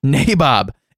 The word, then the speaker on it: nabob: Today's word is nabob. Nabob? – Right, Nabob? – Let's take a listen to this. – Nabob. – Nabob. It is Nabob.